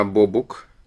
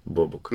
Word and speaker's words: Бобук: Вот, и я обычно неправильно ставлю ударение. – Он... Радиот. – Нет, радиот это ладно, я не про то. Я про то, что он э, Бобук или Бабук. А, Бобук. Бобук.